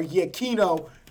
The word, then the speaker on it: Giacchino: Giacchino, honestly. Or Giacchino, as it should be pronounced.